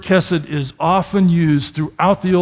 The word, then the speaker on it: chesed: And that word is chesed, and it's spelled C-H-E-S-E-D.